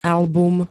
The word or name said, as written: album